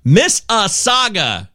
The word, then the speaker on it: Mississauga: Mississauga. No W pronounced.